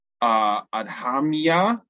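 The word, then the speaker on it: Adhamiya: The handbill target audience is the parents of school-aged children in the Adhamya district. Am I pronouncing that right? You know, are you familiar with that district? Sure. – Okay. No, it looks good to me.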